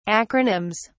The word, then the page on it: acronyms: British English